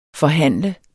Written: forhandler

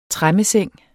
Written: tremmeseng

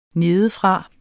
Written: nedefra

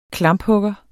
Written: klamphugger

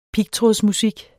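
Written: pigtrådsmusik

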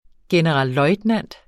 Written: generalløjtnant

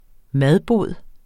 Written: madbod

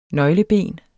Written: nøgleben

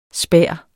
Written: spær

